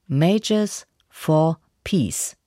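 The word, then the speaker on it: Mayors for Peace: Die Aussprache ist so: Majors for Peace.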